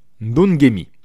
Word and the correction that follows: Ndongeni: Xolisani Ndongeni.